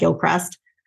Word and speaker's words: Gilchrist: It's spelled like Gilchrist, but said Gilcrest.